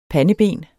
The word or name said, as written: PANDEBENETS